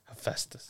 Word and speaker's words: Hephaestus: Hephaestus.